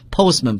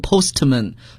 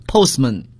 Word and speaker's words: postman: 加了后缀 man 之后呢，t 我们最好不要给它发音，失去爆破。 postman 不要读作 postman。而且尤其是当 man 作为一个名词的后缀时呢，我们不要读 postman，而读 postman。